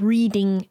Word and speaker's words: Reading: For our American listeners, that's spelled like I am reading a book, but it's Reading. And it is located at 61 St. Mary's Butts. And that is B-U-T-T-S.